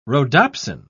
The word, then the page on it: rhodopsin: British English